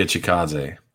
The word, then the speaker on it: Chikadze: Cub Swanson versus Giga Chikadze.